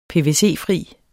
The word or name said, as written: pvc